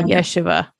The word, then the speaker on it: Yeshiva: Is it Yeshua how you say it? – It's Yeshiva University. – Why? Yeshiva. Thank you.